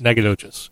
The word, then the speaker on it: Nacogdoches: Nacogdoches? – Nacogdoches, yeah.